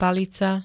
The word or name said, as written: palica